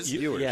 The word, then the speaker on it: Ewers: Ewers.